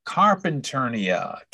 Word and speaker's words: Carpinteria: Is that how you pronounce that? – Carpinternia, California.